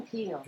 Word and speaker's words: Peele: Jordan Peele. – Jordan ah, Peele.